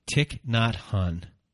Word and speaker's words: Thich Nhat Hanh: So here's a poem that you're going to read by Thich Nhat Hanh.